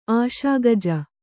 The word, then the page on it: ಆಶಾಗಜ: kannada